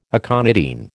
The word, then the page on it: aconitine: American English